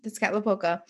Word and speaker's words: Tezcatlipoca: Quetzalcoatl and Tezcatlipoca. Nope. Let me try that again. Tezcatlipoca? No, yeah. I'm definitely putting the emphasis on the wrong syllable. Tezcatlipoca.